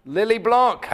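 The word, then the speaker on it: Lillet Blanc: Lily Blanc.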